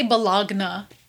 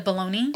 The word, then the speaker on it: bologna: I used to say Bologna. – What is that? – Instead of baloney. – Oh my gosh. – Yep, because that's how it's spelled. Bologna. – Bologna. – Yep, I was like I was like 13 when my stepmom was like, that's not right.